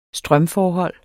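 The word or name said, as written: strømforhold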